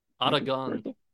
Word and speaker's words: Aragon: is it Aragon? – Aragon. – Article. So I always try to say it right, and it always comes out wrong because I can't like roll my R's.